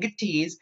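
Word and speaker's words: Mattis: I will be fair to both Pence and Matisse, and yes, that is how it's pronounced. It's a Z, not an S, and I'm never, ever, ever going to let him forget that that's a really stupid way to spell it.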